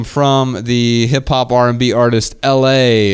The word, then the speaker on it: ELHAE: He is the artist from North Dakota who lives in Atlanta, but his name is LA. But it's not spelled LA like the city. It's spelled ELHAE.